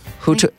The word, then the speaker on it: Hutto: Now, to break down the state more specifically, the longest life expectancy recorded in our state is in, I don't know if I'm pronouncing this right, Hutto. It's near Austin. Hutto, H-U-T-T-O.